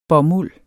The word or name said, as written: bomuld